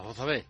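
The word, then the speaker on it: Jozabed: Jozabé, nombre propio. Ya sabrás tú, si es árabe, si es judío. Pero es pronunciación andaluza, Jozabé. – Jozabé.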